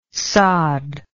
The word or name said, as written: sade